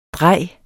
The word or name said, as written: drej